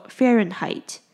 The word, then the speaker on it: Fahrenheit: 那 Fahrenheit 呢，它你可以看到单字中间有一个 H，但是呢，它在这里是不发音的，所以就念作 Fahrenheit。